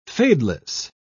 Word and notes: fadeless: British English

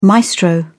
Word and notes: maestro: British English

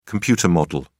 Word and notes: computer model: British English

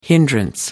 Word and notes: hindrance: American English

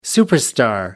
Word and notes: superstar: American English

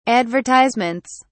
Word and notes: advertisements: British English

